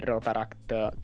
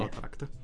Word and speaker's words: Rotaract: Oggi siamo con la presidente del Rotaract Club Roma, e invece con il rappresentante distrettuale per il distretto 2080 del Rotary. Detto bene. – Rotaract. – Ah, c'ero quasi, dai.